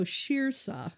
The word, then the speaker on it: Saoirse: S-A-O-I-R-S-E. And yes, it's Irish and it is pronounced Saoirse.